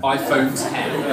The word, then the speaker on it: iPhone X: iPhone X. Yeah. The best phone in the world. – It's pronounced 10. It's 10. – iPhone 10.